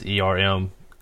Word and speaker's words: Ermias: The release said Hustle, whose real name was Ermas Shandon. I'm sorry I pronounced it. His first name is Ermias, and the last name is Asghedom.